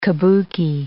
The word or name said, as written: Kabuki